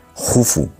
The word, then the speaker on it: Jufu: se escribe Keops, pero se pronuncia Jufu y casi nadie le llama Jufu porque Herodoto nos lo tradujo del griego y bueno, pues ahí se ha quedado, ¿no?